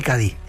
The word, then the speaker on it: Cádiz: Escritor de Cádiz, Cádiz. ¿Se dice bien? – Sí, así? – Señor. – ¿Lo he dicho bien? – Lo ha dicho perfecto.